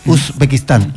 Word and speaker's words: Uzbekistán: Ayer los chicos que jugaron en contra de Colombia, que usted dice muy bien el país, Uzbekistán. Ahí lo dije bien. Sí.